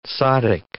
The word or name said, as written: zaddik